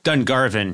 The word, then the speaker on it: Dungarvan: Dungarvan? Dungarvan. Dungarvan. Barbarian. Yeah. Yeah. I'm sorry. It's okay. Dungarvan. Sorry. Got a lot to prove. I guess here I don't care about the Irish. Yeah, Dungarvan.